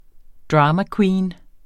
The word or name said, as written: dramaqueen